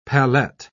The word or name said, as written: pallette